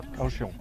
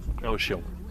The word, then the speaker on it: Kaohsiung: Et le chauffeur lui-même qui s'intéresse à cette élection, notamment dans le sud de Taïwan, dans cette ville clé de Kaohsiung, on prononce comme ça. – Kaohsiung. – Kaohsiung.